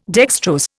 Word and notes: dextrose: Indian

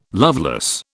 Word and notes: loveless: British English

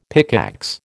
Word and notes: pickaxe: American English

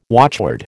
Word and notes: watchword: American English

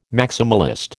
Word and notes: maximalist: American English

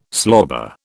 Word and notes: slobber: British English

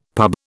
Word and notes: pub: British English

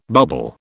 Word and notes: bubble: British English

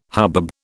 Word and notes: hubbub: British English

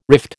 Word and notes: rift: American English

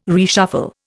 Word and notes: reshuffle: Indian

